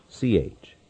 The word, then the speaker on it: Plyushch: I hope I got that pronounced correctly. It is Plyushch.